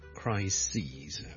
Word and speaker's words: crises: 上一集講嘅最常見嘅例子就 crisis 啦， CRISIS 一個危機啦， a big crisis 啦，但係 number of Crises， OK，留意個發音啊，串字當然係唔同啦，但係發音係唔同嘅。 Crisis 同 crises。